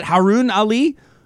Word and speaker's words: Haruun Ali: Is it Haruun Ali? – Yes, you are.